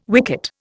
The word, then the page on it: wicket: Indian